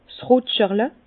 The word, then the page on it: 's Rutscherle: Bas-Rhin; Herrlisheim